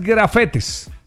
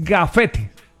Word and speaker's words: gafete: No, porque no es grafetes. – Ah, ok. – Es gafete.